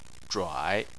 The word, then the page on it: zhuai: 3